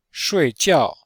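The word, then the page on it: shuijiao: shui4jiao4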